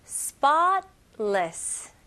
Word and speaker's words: spotless: 여기서 spotless, spotless. 그래서 잘 들어보시면 중간에 있는 t 소리가 그렇게 강하지 않아요.